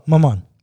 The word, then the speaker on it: Mammon: But what he says here is, more literally, Jesus says you can't serve both God and, help me if I'm pronouncing this wrong, but Mammon? – Let me see. – Mammon? – Oh, Mammon.